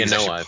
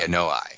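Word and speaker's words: Anoa (Anoaʻi): The Anoa family. Yeah. – Anoa.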